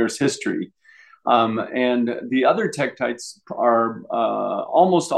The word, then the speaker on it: tektites: Are they called titicites? Did I say that correctly? – Tektites. – Tektites. Thank you.